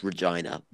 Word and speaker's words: Regina: Actually, it's pronounced Regina, but that sounds a bit, you know.